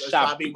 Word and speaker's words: Ashabi: No, it's Ashabi. And I wrote it as Ashabi with an H so that way I can make sure I pronounced it correctly. But it's definitely Ashabi.